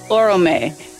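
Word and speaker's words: Oromë: But Vala Orome, is that correct? – Oro, or, uh, Orome. – Orome. Yes. Um,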